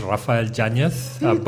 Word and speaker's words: Rafael Yanes: So my name, pronounced the Spanish way, is Rafael Yanes. But after about 15 years in the UK, I've stopped bothering about that, and I just take it as it comes.